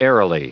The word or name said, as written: airily